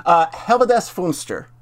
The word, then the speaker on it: Helvedes Fönster: Helvedes Fönster. – No, that's pretty close.